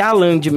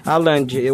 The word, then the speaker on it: Haaland: Não sei se é Haaland, Haaland, mas pelo que eu vi nas pronúncias é Haaland mesmo, né? Isso. né? – Haaland. O H não tem o, o som de R.